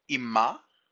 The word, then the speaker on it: ima: So, ima is the word used, which would mean with her, because im in Hebrew means with. So, ima would be with her. That's a feminine H sound at the end.